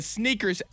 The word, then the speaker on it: SNKRS: It's called the Sneakers app. It's spelled sneakers without the vowels. So S-N-K-R-S.